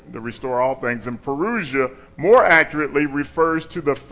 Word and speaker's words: parousia: All right, so the word Advent in the New Testament is actually a word called parousia. That's how it's pronounced, parousia.